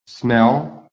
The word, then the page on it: SMELL: American English